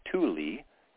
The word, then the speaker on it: Tule: So you can just Google Thule, T-U-L-E. It's pronounced Thule, T-U-L-E elk. Point Reyes, R-E-Y-E-S,